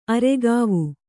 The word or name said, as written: ಅರೆಗಾವು